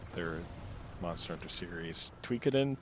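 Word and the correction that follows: Toukiden: Tukaden. – Are you going to say Tokiden? – Tokiden, which was a decent enough series of two games of like Monster Hunter likes that never really found a good identity.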